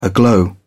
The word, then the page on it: aglow: British English